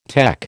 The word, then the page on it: tech: American English